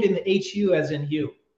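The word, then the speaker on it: Hu: His name is Hugh, but it's spelled H-U, which is Sanskrit for white light or God.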